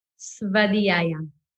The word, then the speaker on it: Svadhyaya: Svadhyaya? – Svadhyaya.